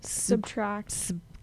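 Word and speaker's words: SBTRKT: It's just Subtract. But it doesn't have vowels and it's in all caps.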